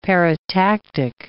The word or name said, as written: paratactic